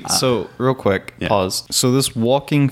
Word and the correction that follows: Joaquin: phoenix man, did I say his name right? Uh, Joaquin. Joaquin. Okay. Joaquin, not walking.